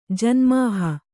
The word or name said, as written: ಜನ್ಮಾಹ